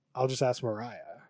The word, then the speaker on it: Moriah: Dude, it's spelled M-O-R-I-A-H. – I know. I keep calling How do you announce that? – Like Moira, enunciate but that. It's Mariah.